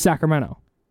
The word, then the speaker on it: Sacramento: But nobody from here says Sacramento. – Okay. – It's just Sacramento. – No, that's true. – And she, the way she speaks is proper, so she wants to pronounce the T. – Oh. – And she had to train herself to not say the T.